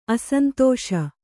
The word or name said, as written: ಅಸಂತೋಷ